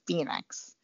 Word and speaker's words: Fenix: Because according to Garmin, I think it's Fenix. – The Fenix? – Yep, because there's a long E line.